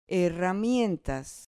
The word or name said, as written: herramientas